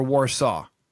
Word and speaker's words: WARZAW: Today on the Rock Metal Podcast, we have, is it WARZAW or WARZAW? – WARZAW. – Okay. – I think you said it right the second time there.